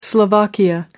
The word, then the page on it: slovakia: American English